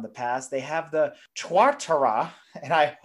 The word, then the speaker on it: Tuatara: I shall drop knowledge now, and it is pronounced Tuatara.